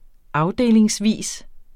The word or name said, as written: afdelingsvis